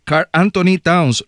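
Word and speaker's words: Karl-Anthony Towns: Marco, te pregunto, no sé qué edad tiene, pero este muchacho, Carl Anthony Towns, lo dije bien. – Sí, así mismo es.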